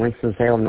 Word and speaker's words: Winston-Salem: Winston-Salem, North Carolina? – You got it right. Winston-Salem, North Carolina.